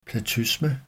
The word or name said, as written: platysma